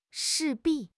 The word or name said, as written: 势必